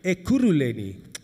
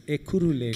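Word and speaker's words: Ekurhuleni: I'm experiencing the kingdom more than I'm experiencing Erkuleni and their bad service delivery. Are you with me? I can never get that word right. How do you say Ekulu? Ekuruleni. Ekuruleni.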